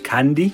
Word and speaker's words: Kennedy: En Kennedy spreek je in het zuiden van Ierland uit als Candy.